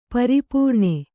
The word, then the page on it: ಪರಿಪೂರ್ಣೆ: pari pūrṇe